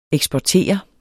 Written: eksportere

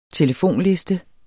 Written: telefonliste

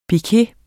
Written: piquet

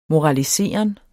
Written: moraliseren

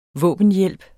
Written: våbenhjælp

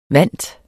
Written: vant

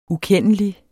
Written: ukendelig

